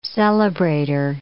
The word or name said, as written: celebrator